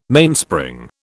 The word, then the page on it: Mainspring: British English